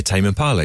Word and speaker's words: Tame Impala: Oh, yeah, Tame Impala, exactly like that.